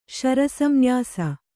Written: ಶರಸಂನ್ಯಾಸ

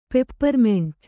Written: ಪೆಪ್ಪರ್ಮಿಂಟು

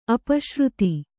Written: ಅಪಶ್ರುತಿ

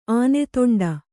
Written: ಆನೆತೊಂಡ

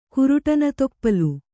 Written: ಕುರುಟನತೊಪ್ಪಲು

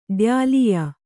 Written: ಡ್ಯಾಲಿಯಾ

